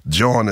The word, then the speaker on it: Jawn: Jawn is a slang terminology only used by Philadelphians. – How do you spell that? – In New York, it would be joint. So Jawn is J-A-W-N.